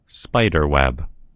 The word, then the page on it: spider web: American English